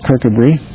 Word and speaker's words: Kirkcudbright: While he was in prison awaiting his trial, he wrote to Thomas Wiley, who's a minister in the town that's spelled Kirkcudbright but pronounced Kirkabry.